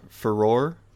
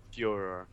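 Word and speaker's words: Firor: The studio general manager is Matt I can't ever pronounce his last name. Furor? – Fjor. – Fjor? – Yeah, Fjor.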